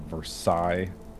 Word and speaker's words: Versailles: They wrote a book about their visit, and they went traveling around Europe, and they're at the Palace of Versailles. Am I saying it right? It's not. – Yeah, yes, that's pretty good.